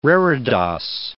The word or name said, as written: reredos